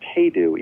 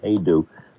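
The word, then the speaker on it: Hajdu: Hey, it's pronounced Hey-Do, even though there's a J. Hey-Do. – Okay, the J is silent. Hey-Do, Lush Life, the story of Billy Strayhorn.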